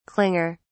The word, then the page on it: clinger: British English